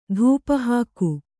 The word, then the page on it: ಧೂಪಹಾಕು: Kannada; dhūpa hāku